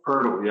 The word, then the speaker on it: Poeltl: Poeltl, yeah.